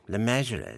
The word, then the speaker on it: Le Mesurier: It's actually pronounced John Le Mesurier, you see, as in treasurer, Le Mesurier.